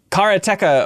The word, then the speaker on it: Karateka: The making of Karateka, of course. Don't pronounce it any other way.